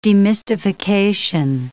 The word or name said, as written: demystification